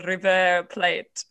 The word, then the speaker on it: River Plate: Now, keeping up with sports, I have to ask, are you a Boca Juniors or River Plate? Now, I hope I also pronounce that correctly. – Boca Juniors or River Plate, you pronounce it perfectly.